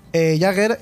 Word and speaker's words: Jugger: El que creó el deporte fue alemán y decidió que se pronunciaba Jagger y se escribía Jugger.